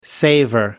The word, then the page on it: savour: American English